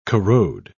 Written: corrode

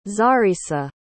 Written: xarissa

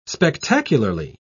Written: spectacularly

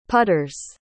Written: putters

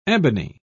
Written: ebony

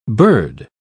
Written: bird

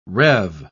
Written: rev

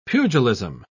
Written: pugilism

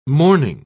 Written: mourning

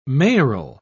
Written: mayoral

